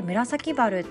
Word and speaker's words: 紫原: で一つ挙げるとあの紫の原っぱって書いて紫原って読む